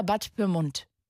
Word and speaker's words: Pyrmont: Sagt man Bad Pyrmont oder Bad Pyrmont? – Bad Pyrmont. – Echt mit T hinten. Klingt so französisch eigentlich. Ich dachte, das spricht man so Pyrmont aus. – Nee, Pyrmont. – Pyrmont, wie der Deutsche sagt.